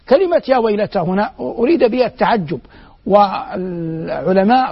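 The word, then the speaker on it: يا ويلتى: يا ويلتا، الألف في يا ويلتا قال بعضهم إنها للاستغاثة، وهي مكتوبة في المصاحف التي بين أيدينا على هيئة الياء وإن كانت تنطق ألفا.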